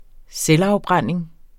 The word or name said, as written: selvafbrænding